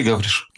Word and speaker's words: Гавриш: Скажите, я правильно делаю ударение на вашу фамилию? – Да, да, все правильно. Алексей Гавриш.